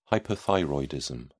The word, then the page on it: hypothyroidism: British English